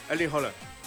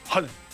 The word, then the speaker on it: Haaland: Bueno, Alfredo González, comentarista de Fórmula Deportes, ¿cómo se pronuncia? – Holland. – Holland. – Holland. – Es con O. – Y es rápido, es Erling Haaland. – Haaland.